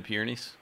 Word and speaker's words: Pyrenees: So did you have snow in the Pyrenees when you went through? Did I pronounce that correctly? – Yeah.